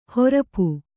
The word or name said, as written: ಹರಪೆ